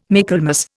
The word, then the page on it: Michaelmas: Indian